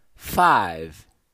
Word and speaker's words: five: Esse é o número 5 e o correto é você falar five. Não pronunciar o E do final, como nós já falamos quando é, tratamos do número 1, um, one. Então você não pronuncia o E.